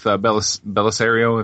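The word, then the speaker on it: Bellisario: But I read an interview it's been a long time since I read this, but with Belisario, if I'm saying his name right. – Yep.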